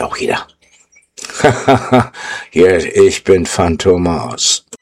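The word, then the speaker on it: Fantômas: Ja, will you, oh Gott, Chase Fantomas, ja. Oder Fantomas wird das, glaube ich, ausgesprochen. Das O mit den Tütelchen oben drauf.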